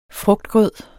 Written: frugtgrød

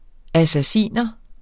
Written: assassiner

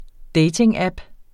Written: datingapp

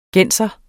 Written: genser

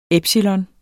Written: epsilon

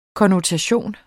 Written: konnotation

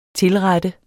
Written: tilrette

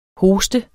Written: hosten

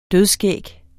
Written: dødskæg

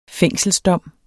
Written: fængselsdom

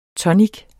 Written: tonic

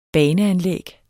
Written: baneanlæg

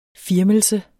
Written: firmelse

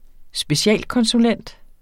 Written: specialkonsulent

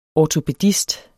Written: ortopædist

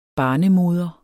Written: barnemoder

